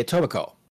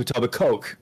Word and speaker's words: Etobicoke: And just before we go any further, folks, he's actually pronouncing it as a non-native speaker, because a native speaker would say Etobicoke. – Okay. – As opposed to a tub of Coke. We don't emphasize the Coke here, folks, because we're not, you know, Coke heads.